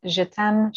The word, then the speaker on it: Jetaime: So je t'aime, Cheree. Je t'aime is spelled the traditional French way, which means I love you, and then Cheree.